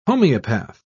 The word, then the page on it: homoeopath: British English